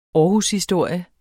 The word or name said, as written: aarhushistorie